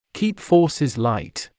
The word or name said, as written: keep forces light